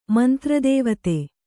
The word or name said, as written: ಮಂತ್ರದೇವತೆ